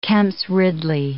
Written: Kemp's ridley

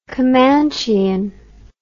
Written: comanchean

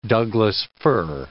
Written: Douglas fir

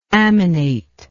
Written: aminate

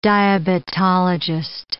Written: diabetologist

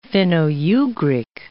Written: Finno-Ugric

